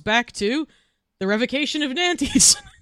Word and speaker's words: Nantes: How to pronounce Nantes. Apparently it is Nantes.